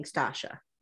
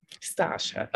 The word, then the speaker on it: Stacia: I was calling her Stasia as well, but I kind of made note when she was saying it, and she was saying Stasha. – Stasha. Okay, so I'm going to have to do like last season with Olajuwon and like phonetically write it out so I don't make the mistake. So yeah, see, just do it with like the S-H-A at the end.